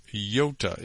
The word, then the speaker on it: iota: For truly I say to you, until heaven and earth pass away, not one iota or yota, actually that should be pronounced yota in the Greek, not one yota, not a dot, will pass away from the law until all is accomplished.